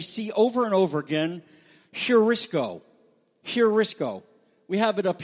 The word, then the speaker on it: heurisko: That means that the word has to be pronounced like an H. If it's going the other way, then there's no affectation at all. So this is heurisko.